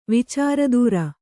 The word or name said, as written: ವಿಚಾರದೂರ